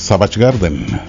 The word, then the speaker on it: Savage Garden: Oye, había un grupo allá por los 90s, 80s, se llamaba, bueno, no sé cómo se pronuncia, es Chaven Garden. Tenía unas baladitas bien padres. Ahí si la tendrás, por favor. Y ahí, corrígeme cómo se pronuncia. – En el segmento Música en Inglés, Savage Garden.